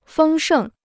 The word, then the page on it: 丰盛: fēng shèng